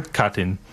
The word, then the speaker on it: Katyn: Katyn.